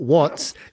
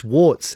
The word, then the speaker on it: warts: No, it's not watts. It's warts. W-A-R-T-S.